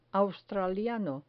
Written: australiano

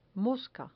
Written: mosca

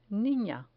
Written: nina

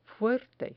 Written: fuerte

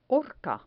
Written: horca

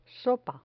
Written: sopa

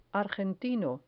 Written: argentino

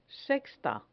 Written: sexta

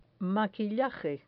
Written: maquillaje

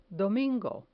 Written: domingo

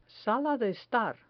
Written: sala de estar

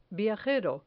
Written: viajero

